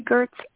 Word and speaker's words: Gertz: Jillian has a soft G, like the word giraffe, and you say Gertz as if it has a T, though you never spell it with one. Gertz is tough because in English it doesn't look like it sounds or sound like it looks. Gertz rhymes with hertz.